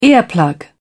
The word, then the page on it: earplug: British English